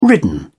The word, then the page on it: ridden: British English